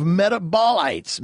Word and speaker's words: metabolites: We have developed technologies, it says, to analyze the biochemistry and ecosystem of our body that consists of millions of metabolites.